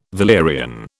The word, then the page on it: valerian: British English